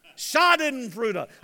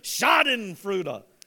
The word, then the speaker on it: Schadenfreude: A UC San Diego researcher, Nadav uh, Goldschmied, theorized that this is the expression of Schadenfreude. Schadenfreude. I didn't even know how to say it until Dennis told me this morning. Schadenfreude.